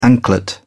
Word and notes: anklet: British English

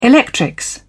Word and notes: electrics: British English

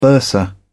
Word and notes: bursar: British English

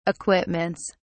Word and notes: equipments: British English

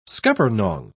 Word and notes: scuppernong: British English

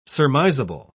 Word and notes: surmisable: British English

